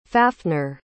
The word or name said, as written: Fafnir